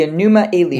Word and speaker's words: Enuma Elish: The Enuma Elish.